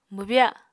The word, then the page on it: mwbya: Apatani